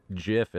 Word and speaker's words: GIF: GIF is actually the GIF. Correct pronunciation.